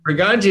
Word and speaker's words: Bourgonje: Bourgonje is fine, yeah.